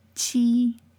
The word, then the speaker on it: qi: Which sounds different than the next word, but can be confusing to the English reader of pinyin. – Qi.